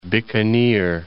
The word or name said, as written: Bikaner